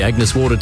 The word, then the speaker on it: Agnes Water: Heading down to Agnes Waters. – Mate, it's pronounced Agnes Water.